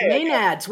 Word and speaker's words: Maenads: I don't know if I'm saying this right. Maenads? – Excellent pronunciation, okay. Maenads, way to go.